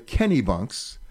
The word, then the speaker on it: Kennebunks: What would be the biggest surprise for a first-time visitor coming here about the history of the Kennebunks? Did I do that okay? Did I say that right? – Yes, you did.